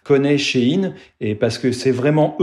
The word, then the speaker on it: Shein: C'est à cette époque que le nom a été raccourci en Shane, alors qu'il faut prononcer du coup à l'anglaise She-In.